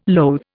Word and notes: loathe: Indian

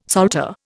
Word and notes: salter: Indian

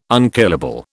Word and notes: unkillable: British English

